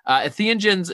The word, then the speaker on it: entheogens: And I don't know if I'm butchering that name or not, but I'm just pronouncing it spells. Yeah, it sounds right. Entheogens, it's a Greek word.